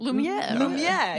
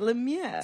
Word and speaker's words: Lumiere: I want to be Lumiere. I feel like I'm the life of the party. Am I even saying his name right? – Lumiere. – You have to say it. Lumiere.